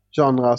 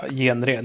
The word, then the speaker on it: genre: Vem uttalar genre som det stavas. Och dels... – genre heter det, för helvete.